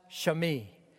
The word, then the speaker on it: Shimei: It's pronounced Shimei.